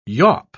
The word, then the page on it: yawp: British English